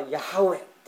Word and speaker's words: ヤハウェ: ですから誰もこの言葉は発音が分からなかったわけです。でも聖書学者によると、それはヤハウェというような発音ではなかったかと言われるわけです。